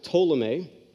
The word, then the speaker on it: Ptolemy: To the south of Israel, what's known as Egypt, there's a general by the name of Ptolemy. The P is silent. Ptolemy is ruling that, and it becomes the Ptolemaic Kingdom.